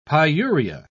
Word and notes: pyuria: British English

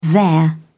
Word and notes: their: British English

